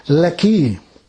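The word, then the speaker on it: Lehi: I was looking, wondering how you pronounce that name, and I found out it's Lekki. It's not at all the way it's spelt there.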